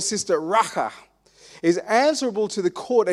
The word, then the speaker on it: Racha: Everyone say Racha. Racha. Racha. Chidi, how do you say that? Racha. Racha. It's almost like you're spitting, isn't it? That's what it is. It's kind of this thing like I spit in your face.